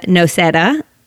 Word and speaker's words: Noceda: Welcome wellness blogger Olivia Noceda.